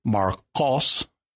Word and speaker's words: Marcos: Mark is Marcos, and you're pronouncing the Q towards the back of your mouth there, not the K towards the front.